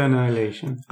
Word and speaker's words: Annihilation: Men det uttalas ju Annihilation.